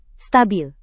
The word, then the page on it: stabil: Indonesian